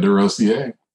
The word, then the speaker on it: Desrosiers: Very good, Desrosiers.